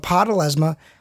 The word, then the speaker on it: Apotelesma: Next Friday, which is the 5th of April, brand new show, first episode ever of a Apotelesma.